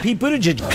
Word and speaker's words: Pete Buttigieg: So Pete Buttigieg, mm-hmm. which that's how you say it. That's how he said it, I should say. I checked. – Pete Buttigieg.